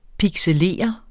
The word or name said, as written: pixelere